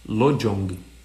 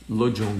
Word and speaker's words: Lojong: Como se escreve Lujon, para quem está no Face no YouTube aqui? L-O-J-O-N de navio, G de gato. Lojong. Lojong, meditação mindfulness.